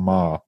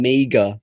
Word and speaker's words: Meagher: We discuss how his name is spelt compared to how it sounds. – I've known John for years, and I've always said John Meager. – Yeah, it's spelt M-E-A-G-H-E-R. – John Marr. – So Irish spellings to pronunciations, mate.